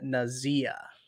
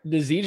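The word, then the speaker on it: Nazeeh: Gore, Baker, Bouchel, and Nazia. That's not how you say his last name. Nazi? That's how you say it. – Nazee Johnson is going to be the – oh, sorry, from least to most.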